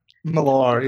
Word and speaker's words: Millar: No, is that how it's pronounced? Millar? – Millar, yeah. Cool.